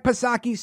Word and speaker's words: Psaki: Now, of course, Jen Circleback Psaki, Silent P, she couldn't report on anything until 9:30 a.m.